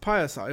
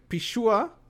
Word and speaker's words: Pixiu: It's actually P-I-X-I-U, and the pronunciation is something like Pishua, Pishu, or something like that.